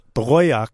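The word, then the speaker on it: Towaoc: It's the name of the community at the Ute Mountain Ute Tribe, which is, you may pronounce it as Toyoc. – Toyoc. – It's pronounced Toyoc.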